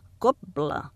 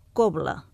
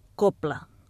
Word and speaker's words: cobla: Segons Lièc, ho podem dir amb B geminada, cobla, o amb B continuar, cobla. En canvi, no es considera recomanable la pronunciar amb P, cobla.